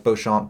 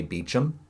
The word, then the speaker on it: Beauchamp: I say it like Beauchamp, but uh, English people normally say Beecham. – Oh. – But I don't know. And New England definitely says Beecham.